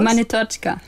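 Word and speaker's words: Monetotschka (Монеточка): Wie war das zum Beispiel mit Monetorga? – Wie spricht man das äh, richtig aus? Monetotschka. Monetotschka, okay.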